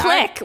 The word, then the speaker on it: clique: Wait, it's pronounced click. How many times am I going to have to say this? – Oh, sorry, click.